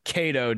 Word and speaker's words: Kado: Tonight, we are joined by fan of the show, Robert Cato.